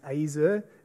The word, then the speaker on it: ezer: The original word is azer. It's spelled E-Z-E-R, but it's pronounced azer, even with an Aussie accent.